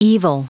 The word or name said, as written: evil